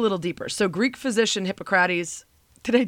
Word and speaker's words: Hippocrates: Hippocrates pronunciation, like Hippocratic oath, Hippo. Hippocrates. I knew I was going to do that. Hold on. Here we go. Hippocrates. That doesn't make sense, Spain.